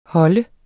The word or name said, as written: holder